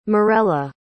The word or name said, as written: morela